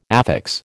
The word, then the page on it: affix: American English